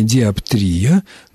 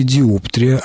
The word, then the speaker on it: диоптрия: И по поводу существительного диоптрия, Словарь Ирины Леонидовны Резниченко, орфоэпический словарь русского языка, рекомендует ударение диоптрия, но разрешает ставить ударение и на втором слоге диоптрия, однако замечает, что этот вариант является устаревшим.